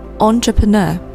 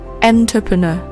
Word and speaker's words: entrepreneur: 係啦，企业家嘅英文呢正確嘅读音呢就係 entrepreneur 咁样啦。咁但係有好多人呢就会读成可能 entrepreneur 之类嘅咁样嘅读音啦，佢哋可能会唔知道佢嘅一开头呢个 vowel 咧系 on 咁样嘅音啦，